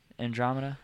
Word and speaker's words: Andromeda: Killing Floor 2, Mass Effect, Andromedum, is that how you say it? – Andromeda, I'm butchering it.